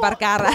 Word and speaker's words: Vergara: Good on you for the correct pronunciation of Vergara. Sofia Vergara.